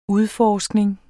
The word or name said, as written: udforskning